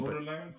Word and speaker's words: Baudelaire: Baudelaire. But, yeah. Um, and you had to. Um, Baudelaire? Baudelaire. There we go, Baudelaire. I think the Simile and Simulacrum. – Yeah. – Yeah, yeah. That, that's Baudelaire.